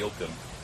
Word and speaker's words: Ogham: Ogham, or the Uam as it should be pronounced in Gaelic.